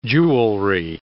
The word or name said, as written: jewelry